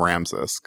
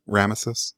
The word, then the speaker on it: Rameses: And then, but I said, I called him Ramses because I was a stupid little Oklahoma kid, didn't know how to pronounce Rameses.